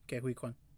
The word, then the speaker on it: Ke-Huy Quan: Yeah, Ke-Huy Quan.